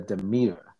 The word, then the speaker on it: Demeter: So today, me and my special guest friends are here to review the last voyage of the Demeter.